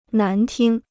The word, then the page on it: 难听: nán tīng